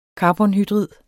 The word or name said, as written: karbonhydrid